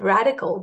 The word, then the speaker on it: RDCL: It's RDCL stands for, you pronounce it radical, but the mnemonic means R for real pain points.